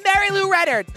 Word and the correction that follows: Mary Lou Retton: I've really butchered that name. – Mary Lou Retton, Marcia Clark.